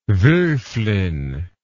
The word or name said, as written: wolfflin